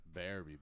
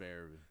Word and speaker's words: Berube: Berube. That's how you say it for real? – It's Berube. – It's Berube? – Yeah. – I've been calling you Berube. – Well, here's the thing. There's Craig Berube. – Uh-huh. – He's saying his name wrong.